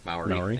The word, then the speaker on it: Maori: However, we pronounce Maori troops. Maori. Thank you.